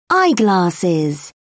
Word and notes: eyeglasses: British English

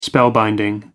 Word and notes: spellbinding: American English

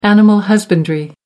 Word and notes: animal husbandry: American English

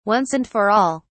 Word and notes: once and for all: British English

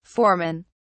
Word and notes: foremen: British English

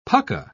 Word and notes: pucka: British English

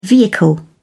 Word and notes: vehicle: British English

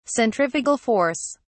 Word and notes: centrifugal force: British English

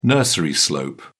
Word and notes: nursery slope: British English

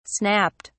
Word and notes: snapped: British English

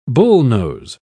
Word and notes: bullnose: British English

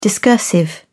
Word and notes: discursive: British English

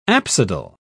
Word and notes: apsidal: British English

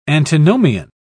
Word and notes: antinomian: British English

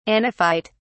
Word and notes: anaphyte: British English

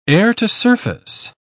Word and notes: air-to-surface: British English

